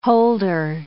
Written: holder

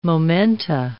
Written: momenta

